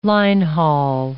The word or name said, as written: line-haul